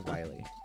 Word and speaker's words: Wily: Dr. Willy really early on. But then I was like, oh, it's only got one L. Dad! And he's like, it's pronounced Wily.